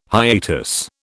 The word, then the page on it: hiatus: British English